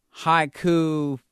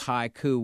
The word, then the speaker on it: haiku: Haiku is pronounced haiku, whether it's plural or singular, so it's haiku.